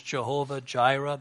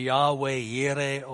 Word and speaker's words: Jehovah Jireh: We sometimes have to say in English, Jehovah Jireh. That's a very poor pronunciation of the Hebrew, by the way. The Hebrew would be Yahweh Yireh or Adonai Yireh. A devout Hebrew would not say Yahweh. They'd say Adonai Yireh.